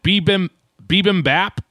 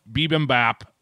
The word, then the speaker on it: bibimbap: Is it bibimbap? Is that how you say it? – That's close enough for comfort, I think.